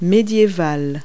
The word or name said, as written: medieval